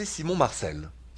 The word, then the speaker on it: Simon Marcel: Bonjour et bonsoir, c'est Simon Marcel. I like to say my name in French because, after all, that's where I'm from.